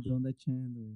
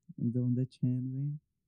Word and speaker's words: Donda Chant: Mm, siento que, le hicieron mucho, mucho énfasis a, a eso, porque Kanye West en eh, Mm, Donda Chorus, creo que se, pronuncia, ¿no? – Oh, Chant, no, Donda ah, Chant. – chat no, Donda Chant, Sorry, güey, qué, qué pendejo estoy, pero bueno. Este, Donda Chant, Este.